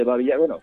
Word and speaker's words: Babi Yar: No sé si lo digo bien, es Babi Yar, es un barranco. ¿Qué ocurrió allí? – Exacto. Sí, esto es el famoso barranco de Babi Yar,